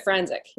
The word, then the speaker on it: Franczyk: Franczyk, yeah.